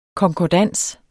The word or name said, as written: KONKORDANSEN